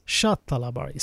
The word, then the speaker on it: Sattalabari: Actually, it's pronounced as Sattalabari, seventh floor apartment.